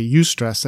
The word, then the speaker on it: eustress: When I say eustress, that's spelled E-U-S-T-R-E-S-S, eustress.